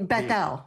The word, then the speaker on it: Bethel: Oh, you, Bethel. Okay. You're saying Bethel? – Yeah, two separate words. Yeah. Beth El. It means house of God, because Beth in Arabic and Hebrew means house.